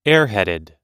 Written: airheaded

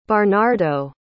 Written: barnardo

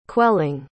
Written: quelling

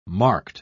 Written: marked

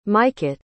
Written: mykyt

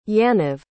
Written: yaniv

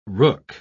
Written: rook

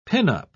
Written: pinup

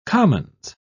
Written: commons

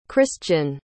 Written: kristian

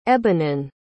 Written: ebhanan

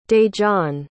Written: dejon

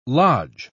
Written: lodge

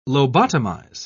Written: lobotomize

